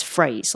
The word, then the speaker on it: Frase: But then we're using this other tool called Phrase, and it's spelt with an F, but I can't remember exactly. We can put the link in the show notes, but it's Phrase. Like, it's playing off the word phrase, but with an F.